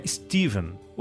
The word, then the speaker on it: Stephen: O Paulo André, de São Paulo, escreveu para dizer que no episódio 2 sobre o After the Gold Rush, do Neil Young, eu passei o episódio inteiro chamando o Stephen Stills de Stefan. Mas a pronúncia correta, como você observou agora, é Steven.